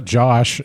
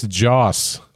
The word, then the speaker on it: Joss: So point one to Josh Whedon. – It's not Josh. It's Joss. OSS.